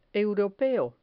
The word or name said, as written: europeo